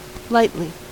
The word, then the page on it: lightly: American English